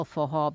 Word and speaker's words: forró: And then I always forget that I need to spell forró, because it's pronounced forró, but it's spelled F-O-R-R-O, forró.